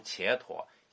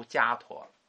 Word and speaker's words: 迦陀: 其实不应该读伽陀，应该读迦陀。